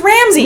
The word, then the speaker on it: Ramesses: Ramesses? – Wait, can I say something real quick? Why do they say his name like that? – I don't know. – Ramesses. I always thought it was Ramses.